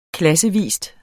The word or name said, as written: klassevis